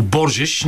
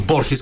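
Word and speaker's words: Borges: За съжаление Григор Димитров вчера не успя да се справи с коравия португалец Нуно Боржеш. Не знам защо никой не му казва Борхес, както бе на испански, но айде ще решим, че а, португалските ударения ще така е, бъдат е, и произношения.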